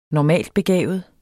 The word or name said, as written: normaltbegavet